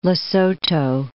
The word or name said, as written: Lesotho